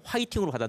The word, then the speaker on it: 화이팅: So in Korea, we don't have a letter to express or pronounce the F letter, F. That is why we change it to like 화, the H pronunciation. And so we read fighting as 화이팅, and that has been in turn spread to the world.